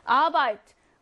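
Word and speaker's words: Arbeit: 아바이트. 강세가요, 독일어 단어들은 다 이렇게 좀 앞에 있어. 아바이트예요.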